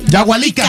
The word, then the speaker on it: Yahualica: Yahualica, Jalisco.